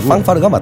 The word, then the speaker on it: 팡파르: 아, 원래 그 발음이 빵빠레라고 하는 원래가 빵파르가 맞답니다.